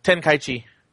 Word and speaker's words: Tenkaichi: So, next, Dragon Ball Z Ultimate Tenkaichi. Did I say that right? – Tenkaichi. – Close enough.